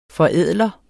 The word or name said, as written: forædler